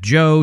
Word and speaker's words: Joe: That was a name given to me years ago, and I think it had something to do with the fact that Joe sounds like pro, Checka Pro. So Checka Pro Joe became my name here on the radio. – Rhymes with pro.